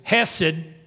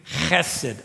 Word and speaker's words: hesed: That is a Hebrew word that is spelled H-E-S-E-D. We might say in West Texas, hesed. But to say it in Hebrew, it's actually hesed. And you have to say it like you're trying to hawk something up.